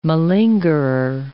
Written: malingerer

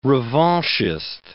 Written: revanchist

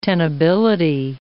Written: tenability

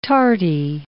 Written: tarty

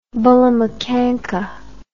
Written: bullamakanka

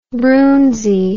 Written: broonzy

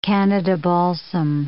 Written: Canada balsam

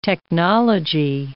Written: technology

